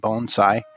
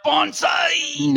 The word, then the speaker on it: bonsai: So the question of how to pronounce the word bonsai is, it's a very often mispronounced word, but the way I'm saying it is the correct way of saying it, bonsai. And I want to take a little... – So it's not bonsai!